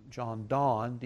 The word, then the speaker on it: Donne: John Donne, D-O-N-N-E, is spelled, pronounced John Donne.